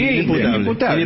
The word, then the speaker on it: inimputable: Inimputable. – ¿Se dice así? – Sí, sí, inimputable.